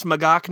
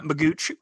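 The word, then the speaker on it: McGeoch: So it's pronounced McGaugh, not Magooch. – I've heard it's McGaugh like Loch Ness.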